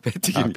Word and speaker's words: 패티김: 팬, 팬티김이요? – 아니요, 패티김. 아, 패티김. – 아, 패티김이라뇨. 발음을 좀 네. 잘해주세요.